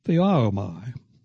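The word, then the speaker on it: theaomai (θεάομαι): For the verb he uses is pronounced theaomai. We get our word theater from that.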